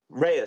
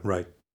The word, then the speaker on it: Rhea: Yes, I saw Ray. Ray Seahorn. – Rhea Seahorn, is it? – No, Ray. – Ray. – Mm-hmm. – Ray Seahorn.